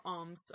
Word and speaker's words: Oms: I don't know if we mentioned that, but like O-M as in like Om. – Yeah, yeah. – But it's a play on the French pronunciation of the word home.